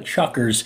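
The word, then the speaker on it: Chukars: you look at it, you could think it's pronounced differently, but they are the Chuckers.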